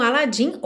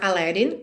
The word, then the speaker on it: Aladdin: Então, após esse diálogo inicial, que como eu falei, tá lá no PDF, começa então com Aladdin or Aladdin cantando I can show you the world.